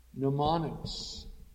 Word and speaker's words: mnemonics: Mnemonics. And that word, and that is spelled M-N-E-M-O-N-I-C-S, the M being silent in English, mnemonics, that has to do with ways of remembering.